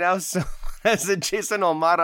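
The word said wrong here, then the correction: Jason O'Mara: I'm like, that's not how you say it. Okay, I'm sorry. Jason Omara as Jeffrey Mace.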